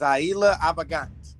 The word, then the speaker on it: Zaila Avant-garde: It's Zaila Avant-garde.